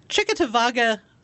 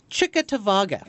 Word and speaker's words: Cheektowaga: In Chickatawaga, New York, which I actually looked up to figure out how to pronounce because it's spelled Cheektowaga. – And it's pronounced what? – Chickatawaga.